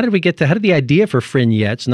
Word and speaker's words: Fringettes: has gone online with something that looks like Fringettes, but I guess it's pronounced Frignettes, I'm told, by Jade Hales, who's the music producer for the Oregon Fringe Festival.